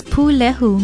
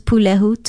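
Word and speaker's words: pūlehu: Although it most correctly means broiling, as you would sweet potatoes, breadfruit, or bananas placed on hot embers, we often use pulehu to describe meat that is broiled. It is spelled P-U-L-E-H-U with a stress or kahako on the first syllable.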